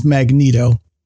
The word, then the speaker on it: Magneto: Apparently, the official pronunciation, sidebar, the official pronunciation of the Marvel Comics villain who has control over magnetism, apparently the official pronunciation is Magneto.